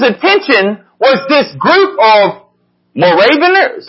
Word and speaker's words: Moravians: Moravians, that's how you say it.